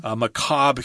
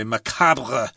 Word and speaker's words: macabre: Etre, I think, E-T-R-E. Macabre here. You wouldn't pronounce the R-E if you're speaking English. I suppose if you're speaking French, you'd say macabre or something like that. But in English, we just say macabre.